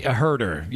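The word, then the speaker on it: Huerter: Kevin, well, how you say it, Hoarder? – Herder. Yeah, Kevin Herder.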